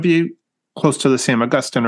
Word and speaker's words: Augustijn: How would you pronounce that in Dutch? Is that Dutch or is that Flemish? – That would be Flemish, which is close to the Dutch. I would say Augustine.